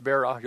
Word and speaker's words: Berra: Wise philosopher Yogi Berra, don't say the name right, so it's Berra, Yogi Berra, once said that when you come to a fork in the road, to take it.